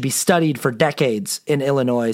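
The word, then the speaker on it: Illinoise: There is an E at the end of it because it's Illinois,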